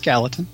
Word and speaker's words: Gallatin: It's gal and then Latin. – Yeah. But the L's are together. Is it like a Y like Spanish? Is it Gayton? – No, it's Gallatin.